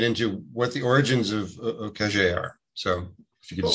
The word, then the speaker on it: Ukheshe: Ukheshe, is that close enough? Good pronunciation? – Close enough. Ukheshe, Ukheshe.